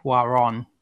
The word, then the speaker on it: Cuarón: Cuarón.